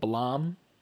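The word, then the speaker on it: Blam!: I still haven't had a chance to read it yet, but it's called Blom. – Blom. – Yes, it's spelled as the word blame with an exclamation point, but it's pronounced Blom.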